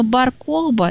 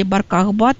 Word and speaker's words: Бар-Кохба: И вот этот самый Бар-Кохба или Бар-Кохба, там по-разному иногда ставят ударение, который объявил себя мессией, и он тоже чеканил монет.